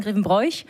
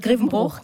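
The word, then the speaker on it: Grevenbroich: du bist in Grevenbruch geboren, spricht man das so aus? – Ja, richtig. Die meisten sagen Grevenbräuch. – Ja, genau. – Das heißt Grevenbruch, genau.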